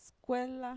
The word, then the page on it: skuela: Mixtec